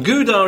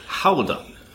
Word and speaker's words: Gouda: Pronounce it. Howda. But you need the phlegm.